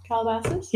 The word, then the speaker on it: Calabasas: Calabasas? – Yeah.